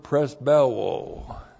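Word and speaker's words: presbeuo (πρεσβεύω): And then we have the verb presbeuo. Looks like this in the Greek. Presbeuo, that's P-R-E-S-B-E-U, long O, presbeuo.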